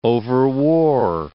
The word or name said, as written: overwore